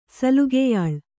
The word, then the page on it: ಸಲುಗೆಯಾಳ್: Kannada